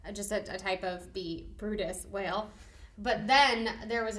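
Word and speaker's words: Bryde's: Okay, so I would say that's brides, right? Or brids. That would be weird. But brides is how that would be. No, Brutus. – Yep. It's pronounced Brutus whale. – Brutus whale. Makes zero sense.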